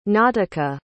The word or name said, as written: nautica